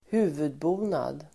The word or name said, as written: huvudbonad